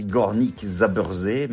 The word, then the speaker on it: Górnik Zabrze: Ça s'écrit Górnik Zabrze, mais ça se prononce Górnik Zabrze.